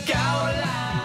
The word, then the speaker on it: Gauland: That's according to one of the many German satires about the elderly, tweed-wearing leader of the AfD, Alexander Gauland. Rhymes with Final Countdown, sort of. – Alexander Gauland.